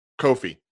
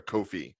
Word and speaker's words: Kofi: Just no, it's Kofi. Years. Kofi. – Kofi. – Like Coco Beware. Kofi.